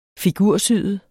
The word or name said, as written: figursyet